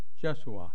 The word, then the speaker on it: Yeshua: What I wanted to do is continue talking about our way-shower, Jesus. Pronounced Jeshua in Aramaic.